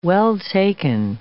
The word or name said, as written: well-taken